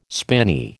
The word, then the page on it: spinney: American English